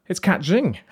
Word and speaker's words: Zień: We're also joined, it's Kat Zhing.